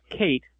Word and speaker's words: Caite: We have I'm assuming this is pronounced Kate, C-A-I-T-E, quote unquote the Rod.